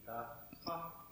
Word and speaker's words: dukkha: So is it pronounced dukkha? Like, do you separate the K and the H at the end? – Yes, if you're able to do that.